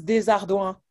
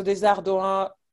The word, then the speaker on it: Desardouin: Yes, Desardouin. So Desardouin, like the trick here is like, so the O-U-I-N at the end, O-U sounds like OU, and then I-N is one sound as well, and it sounds like UN.